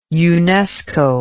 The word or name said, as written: unesco